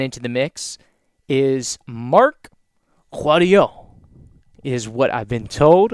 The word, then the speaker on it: Kouadio: Marc, M-A-R-C, and the last name is spelled K-O-U-A-D-I-O. Guadio. Guadio.